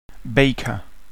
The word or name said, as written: baker